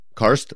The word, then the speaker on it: Karst: And that's usually kind of an indication that there may be a car system nearby or something. These are what the car system. What is that? How do you spell that? – Karst. K-A-R-S. Karst.